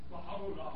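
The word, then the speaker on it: Baha'u'llah (Baháʼu'lláh): Baha'u'llah.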